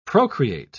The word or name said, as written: procreate